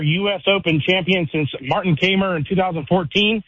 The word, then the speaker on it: Kaymer: I was going to – I like you corrected the pronunciation and he said it again. Kaymer. Yeah, right, Kaymer.